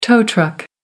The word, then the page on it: tow truck: American English